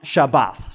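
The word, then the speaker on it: Shabbat (שבת): And the verb in Hebrew is pronounced Shabbat. So you can hear that that sounds like Sabbath.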